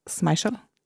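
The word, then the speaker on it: Schmeichel: Du hadde til og med statistroller som Peter Smeichel. Uttalte jeg rett? Ja, veldig bra uttale.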